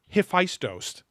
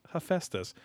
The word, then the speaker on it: Hephaestus: Now, when you think of the story of Hephaestus, and I'm like, nobody calls it Hephaestus. It's Hephaestus.